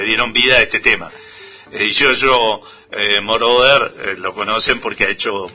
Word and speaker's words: Moroder: Así es, Moroder.